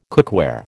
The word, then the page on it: cookware: American English